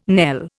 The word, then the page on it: knell: Indian